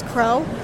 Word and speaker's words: Crowe: So currently, Ignite is only available in print, in person, or you can reach me on my Instagram at Carice Crow. And I know my name is not normal, but it's spelled C-H-A-R-I-S. Last name is Crow, C-R-O-W-E.